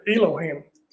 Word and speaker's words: Elohim: I'd just like to say one of the things that I found in reading your notes is about the eloquium. Is that, am I saying that right? – Yeah. – Elohim. Elohim.